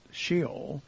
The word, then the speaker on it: Sheol: In the Old Testament, the meaning of the Hebrew word Sheol, and that's the Hebrew word, it actually is pronounced Sheol.